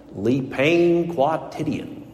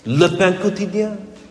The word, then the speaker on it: Le Pain Quotidien: And of course, for years now, my family, in a cruel effort to torment me and my love of all things French, would deliberately go out of their way to butcher the French pronunciation in my hearing, and they would call it Le pain quotidien. And yet I still love them. Le pain quotidien, the daily bread.